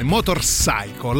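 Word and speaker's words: Motorpsycho: Motorcycle, la P non si pronuncia, come il film di Hitchcock, Psycho, in inglese.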